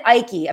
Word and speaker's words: Icke: So David Icke, says Malaka Tom. Okay, fine. David Icke apparently is how you pronounce this guy's last name.